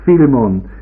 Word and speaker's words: Philemon: So Philemon, sagt irgendjemand hinten, und ich denke, das ist wohl die richtige Aussprache davon.